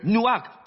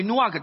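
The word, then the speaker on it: nuach (נח): 而挪亚的名字是 nuach，他们发音为 nuach，大家一起说 nuach，只有两个字母 nun 和 han。希伯来文我在引用字母，就像 a 和 b，对吗？我说的是希伯来文的 nun 和 han， nuach。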